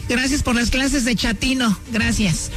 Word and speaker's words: chatino: Chapino. – ¿Chapino? – No, chatino. – Es como chato, ¿verdad? Chatino. – Sí, así me era como chato, chatino.